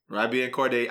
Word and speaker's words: YBN Cordae: How you pronounce that, Raj? – That's it. – YBN Cordae.